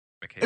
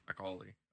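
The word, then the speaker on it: Macaulay: McKaylee. It's a good, I don't know how you say his last name. – McCauley.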